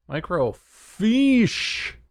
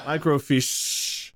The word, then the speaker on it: Microfiche: Microfiche. Emphasis is on the sh. Microfiche. Okay.